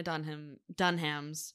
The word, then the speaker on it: Dunham: I would love to know what Lena Dunham's. Well, that's how we pronounce it, by the way. – Dunham.